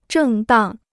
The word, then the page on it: 正当: zhèng dàng